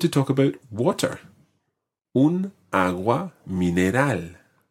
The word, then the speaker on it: agua: Un agua mineral. – Now, un agua mineral. Listen to the way I say agua. Agua. – Agua. – Agua. – Agua. – Okay, it's a very, very soft G. Agua.